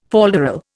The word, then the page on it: falderal: Indian